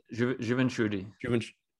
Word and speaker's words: Juventude: But how do you pronounce the job? – Juventude. – Wow, it's a tough one.